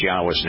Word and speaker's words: Yahweh: There is no question that the Yod Heh Wah Heh is Yahweh's name, and that it is pronounced Yahweh. That there is no question. Now, is it possible that we are putting an emphasis on the wrong syllable? Is it possible that the vowel O is more of an oo sound than an O sound?